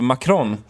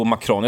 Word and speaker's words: Macron: Det var ju den här utfrågningen av Macron. – Ja. – Och jag säger ju här ett svenskt uttal på Macron. Jag säger inte Macron.